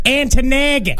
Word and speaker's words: Ontonagon: Yeah, I think it's pronounced Antonagon. – That sounds right. Yeah, Antonagon. Yeah.